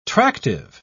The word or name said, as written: tractive